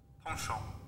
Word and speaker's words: penchant: Also, while you while you think about that, Penchant. That's how you pronounce oh, penchant.